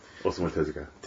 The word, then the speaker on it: Osamu Tezuka: Omar Tozaku, I believe is his name. – Osamu Tezuka.